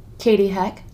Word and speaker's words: Katie Heck: Katie Heck. – And can you spell it? – K-A-T-I-E-H-E-C-K.